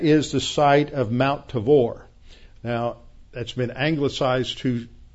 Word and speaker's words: Tabor: So if you hear about the Tabor, that's how it's pronounced. It's Mount Tabor.